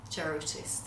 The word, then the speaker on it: Jarutis: We're at a naturopathic clinic, and I'm talking to the holistic Dr. Modestas Charoutis.